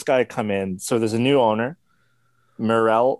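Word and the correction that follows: Meruelo: Alex Maruello.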